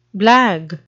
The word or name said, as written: blag